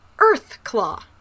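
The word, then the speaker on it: Urthclaw: Earthclaw. – Yeah. Well, there, it's. – Whose name is spelled U R T H Claw. – Again, but it's pronounced Earthclaw.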